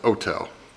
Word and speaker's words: hotel: But if you see an Italian and they see what to them is a foreign word, they see hotel, they'll pronounce it hotel, you know, which, so they'll drop that H, you know, just can't deal with an H, as a starting letter,